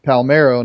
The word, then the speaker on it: Palmeiro: That Palermo, I hope he's a Cub for a long time." And Steve Stone would say, "Well, Harry, I think you pronounce his name Palmeiro, not Palermo."